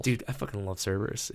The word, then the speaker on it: Cerberus: Cerberus. Or Kerberos, depending on your pronunciation.